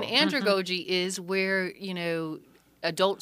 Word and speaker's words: andragogy: And in your paper, you had a section on how adults learn, and you used the word andragogy. What's that? Did I pronounce it correctly? – You did, andragogy.